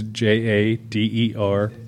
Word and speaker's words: Jaderborg: Todd Jaderborg. And the last name is Jaderborg.